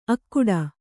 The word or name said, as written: ಅಕ್ಕುಡ